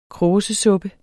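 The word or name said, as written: kråsesuppe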